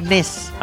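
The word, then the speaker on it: Nys: Se escribe Nis, pero al parecer en holandés se pronuncia. Bueno, en. – Nice. – Nes. – Ah, Nes.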